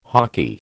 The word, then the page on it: hoky: American English